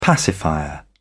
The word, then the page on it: pacifier: British English